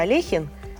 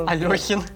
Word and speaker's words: Алехин: Например, фамилия известного шахматиста Алехин, постоянно... – Алёхин. – Да, везде звучит как Алёхин.